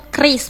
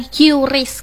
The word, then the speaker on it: QRIS: Jadi cara bacanya yang benar dah dibenehkan juga ya, bahwa cara bacanya tuh kris bukan QRIS kerandeng sana ya.